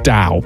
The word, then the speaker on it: dhow: My traditional Dahow ship, or I believe it's just pronounced Dow, but it's spelt D-H-O-W, became a symbol of my seafaring with its distinctive triangular sails.